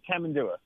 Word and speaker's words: Tamandua: Am I pronouncing that right? – Yeah, we say Tamandua.